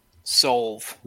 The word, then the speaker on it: Sol: Sol is the silver one. Sol. – Like S-O-L-V-E? – Yeah, that's how it's spelled, but without the E. – Okay, soul. Well, yeah, otherwise it'd be solve.